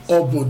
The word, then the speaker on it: Obo: But eating the glass of fire, we call that Obo dance. O-B-O. – Okay. – O-B-O.